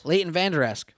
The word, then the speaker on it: Leighton Vander Esch: Leighton Vander Esch. Did I say it right? – Yes. – Leighton Vander Esch.